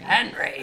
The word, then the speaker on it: Henri: It is Henri. It's French. – But I don't want to say that. So I'm just going to call him Henry. – Henry!